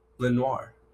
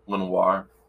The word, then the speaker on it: Lenoir: And then they get another defensive back, which I don't even know how to say this dude's name. D'Amador. – Oh my god. – Sure. – Lenoir. – Lenoir. He's, again, they're just adding more to their cornerbacks because they need to fill many gaps back there.